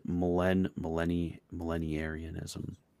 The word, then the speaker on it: millenarianism: So this is how you pronounce it: millenarianism. Is that how you do it? – Yes.